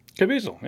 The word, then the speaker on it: Caviezel: Caviezel. – Caviezel, yeah.